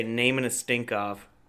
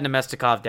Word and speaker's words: Namestnikov: I always say naming a Stinkov. – Naming a Stinkov is pretty good. – Naming a Stinkov. I like that one. – I've always had Namestnikov down. I don't know why. I think I had him on my fantasy team once and I got it down.